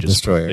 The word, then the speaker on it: Destoroyah: Well, the films still, like, even the Japanese say it as Destoroyah. – Destroyer. – They just spell it Destoroyah.